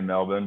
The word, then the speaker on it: Melbourne: Every time I'm telling the story and I say Melbourne, people are like, oh, wow, you're really trying to be an Aussie, you know, and I'm like, that's how you say it. And if I were to, you know, speak to my Australian counterparts and say Melbourne, you know, you sound like a gringo.